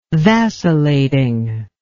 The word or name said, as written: vacillating